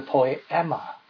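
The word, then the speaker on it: poema (ποίημα): 所以这个字工作，我们的英文叫做 workmanship，它的希腊文的发音叫做 poema，得到我们这个英文单词叫做 poem，诗篇。我们是他的诗篇，我们这群人是他的诗篇。 poema 这个字的意思，这是一个登峰造极匠心独具的杰作。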